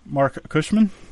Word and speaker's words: Cushman: Marc Cushman? Did I do good? – Yeah, like the Cushman scooter.